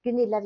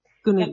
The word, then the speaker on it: Gunilla: Gunilla Wigerts. Gunilla. Jag kallar, ja, på, på, Facebook heter jag Gunilla Alexandra Wigerts därför att jag har utländska vänner som tycker det är så svårt att uttala Gunilla.